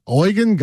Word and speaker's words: Eugen: I think in German, would you agree, fellow German person, that E-U-G-E-N is pronounced Eugen? – Is he Russian or German?